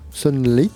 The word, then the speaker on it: Sunlit: Sunlit, oui, c'est prononcé comme ça, s u n l t C'est le nouveau projet de Joe Moore.